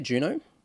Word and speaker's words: Juno: Is that how you say it, Juno? – Yes.